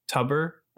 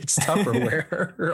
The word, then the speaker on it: Tupperware: Tubber. I say Tubber, like T-U-B-E-R. – Tupperware. – Tuber. – It's Tupperware. Oh my God. It's Tupperware. – No, it's not. It's spelled with B's. – No, it's spelled with P.